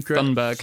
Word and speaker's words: Thunberg: Thunberg.